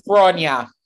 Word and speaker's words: Gráinne: Tell me if I pronounced that wrong. – it's Gráinne.